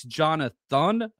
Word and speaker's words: Jonathon: I am J-O-N-A-T-H-O-N. So it's not Nathan at the end of it. It's Jonathan.